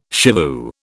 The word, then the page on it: shivoo: British English